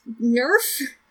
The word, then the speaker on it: NIIRYF: Those are the letters for Not If I Reboot You First, and it is pronounced. Do you want to do the noise? – Nerf? – That's close enough.